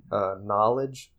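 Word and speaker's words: Knxwledge: And it's by Anderson .Paak and a producer named Knowledge, but it's spelled with an X instead of an O.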